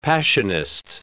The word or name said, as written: Passionist